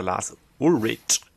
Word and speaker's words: Lars Ulrich: Ja, letztendlich ist es eine Sache, die der Lars Ulrich genau so spricht man aus.